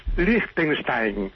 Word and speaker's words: Liechtenstein: No se dice ni Liechtenstein ni Liechtenstein. Es Liechtenstein. Supongo que para el partido de vuelta ya habéis aprendido la lección y no me utilizaréis para un nuevo pitorreo.